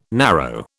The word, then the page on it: narrow: British English